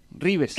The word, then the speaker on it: Reeves: Sería solamente una cuestión La doble E suena como una I, Reeves.